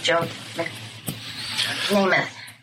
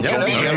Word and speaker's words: Joe Namath: Well, you might know when the New York Jets won the Super Bowl with Joe Namath. Am I getting the name wrong? – No, Joe Namath.